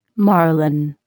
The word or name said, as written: marlin